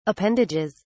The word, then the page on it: appendages: British English